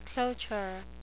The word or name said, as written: cloture